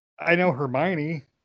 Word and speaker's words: Hermione: I know Hermione. – And you said her name right.